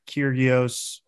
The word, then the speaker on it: Kyrgios: With all due respect, I watched that Kyrgios, is that how you say his name? – Yeah.